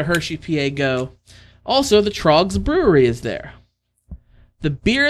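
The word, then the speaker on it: Tröegs: It is Trogs, which we've had Trogs on the show before. It is spelled T-R-O-E-G-S.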